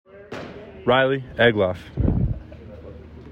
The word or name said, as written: Riley Egloff